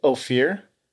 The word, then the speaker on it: Ophir: Ophir. O-P-H-I-R.